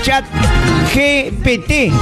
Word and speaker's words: Chat GPT: Ya viene la información al toque con mi pata de chat GPT. GPT. ¿Cómo se llama tu inteligencia artificial? GTPG. ¿Cómo? GPT. Así se llama, ¿eh? No, GPT. T no, T. Demórate un poquito, pero pronuncia bien.